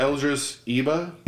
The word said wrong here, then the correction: Idris Elba: Idris Elba. – Idris Elba, he takes on a role that's basically a giant FU to Will Smith, who ruined the first movie.